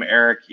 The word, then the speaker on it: Erik: You can use my first name, Eric, E-R-I-K. That's spelled the right way. And Landy, L-A-N-D-I, first, last name.